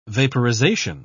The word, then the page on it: vaporisation: British English